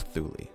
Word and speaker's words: Thule: Is that how you pronounce it? Or Thule? – Thule. – It's Thule. – It's Thule. We talked about it on podcast one.